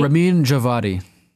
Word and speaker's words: Ramin Djawadi: The HBO fantasy series scored its very first Grammy nomination for composer Ramin Djawadi. Did I say that right? – Ramin Djawadi.